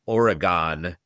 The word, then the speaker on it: Oregon: and they pronounce it Oregon, right? That's like this yeah, that's a tell right away. – Right. – That no, you don't actually know Oregon.